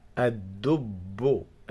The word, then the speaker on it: الدب: أ أ الدو الدو الدو، حرف الدال مشدد وهذه اللام لا تنطق لأنها لام شمسية. الدو الدو بو بو، حرف الباء أيضا مشدد. الدب الدب يأكل الدب يأكل الدب.